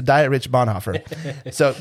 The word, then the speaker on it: Dietrich Bonhoeffer: And the gentleman's name is, well, it's German, so you would say Dietrich Bonhoeffer. – Ooh, nice. – But it's funny because my good friend Travis pronounces his name Diet Rich.